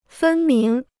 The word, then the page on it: 分明: fēn míng